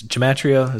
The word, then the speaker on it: gematria: And what they would use is they would basically do gematria, where is gematria? Is that how you say it? – That's how I've heard it pronounced.